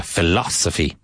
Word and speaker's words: philosophy: Philosophia, philosophy. Emphasis on the second syllable. Philosophy, philosophy.